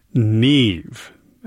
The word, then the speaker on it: Niamh: That's music by Ryan Murphy called Neve. Actually, spelled differently than you might expect, though. It's spelled N-I-A-M-H. It's an Irish word.